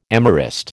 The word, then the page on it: amorist: American English